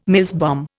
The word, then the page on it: Mills bomb: Indian